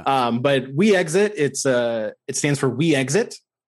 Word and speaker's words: Wexit: we don't want to pronounce it. We exit. That's too much. We'll pronounce it Wexit.